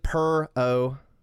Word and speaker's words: Perrault: Yeah, I looked it up. It's like Perrault. – Okay. – Yeah, I don't know if I'm supposed to pronounce it more French, but I'm kind of giving the Americanization of it.